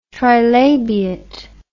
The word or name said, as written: trilabiate